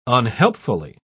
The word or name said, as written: unhelpfully